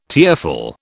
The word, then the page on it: tearful: British English